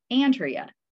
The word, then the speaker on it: Andrea: I did an interview a few weeks ago, and I started out by saying, just so you know, my name is Andrea. And the person said, not Andrea. I said, no, Andrea. That's how I pronounce my name.